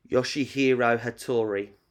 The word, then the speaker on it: Yoshihiro Hattori: Yoshihiro Hattori.